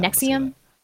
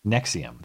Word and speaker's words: NXIVM: It's NXIVM. I don't know how that. – Nexium. – Nexium. That's what it is.